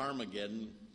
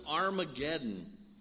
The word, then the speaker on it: Armageddon: Back in chapter 16 and verse 16, it says, they gathered them together into the place which is called in Hebrew, the H is silent in the Greek, but there's an H, a Harmageddon, some people say, but it's Armageddon.